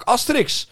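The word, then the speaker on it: Astérix: Daar opende Park Asterix. Of zoals de Fransen zeggen, Park Asterix. Die hebben natuurlijk de klemtoon. – Asterix. – Asterix, die hebben de klemtoon achterin.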